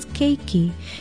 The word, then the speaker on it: keiki: Aloha, ovau o lilani poli ahu of Hawaii Public Radio, and our Hawaiian word for today is keiki. It's a very well-known and often used term for child, and it is spelled K-E-I-K-I.